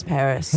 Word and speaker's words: Parius: Oh, yes, but it's spelled P-A-R-I-U-S, Paris.